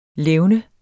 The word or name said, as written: levnes